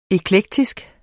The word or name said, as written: eklektisk